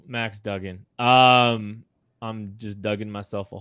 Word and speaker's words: Duggan: Max Duggan, I'm sorry. You said it right. I said it wrong earlier.